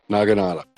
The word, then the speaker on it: naginata: Naga-jaga. – And it's spelled exactly how it sounds. – Naginata. – There you go. Naginata.